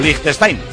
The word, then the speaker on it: Liechtenstein: Su día no se pudo jugar por la amenaza terrorista, y el lunes ya de clasificación para el próximo mundial contra Liechtenstein, o Liechtenstein, que es como se pronuncia.